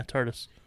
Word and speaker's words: TARDIS: Yeah, TARDIS.